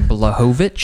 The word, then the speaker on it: Blachowicz: Blachowicz.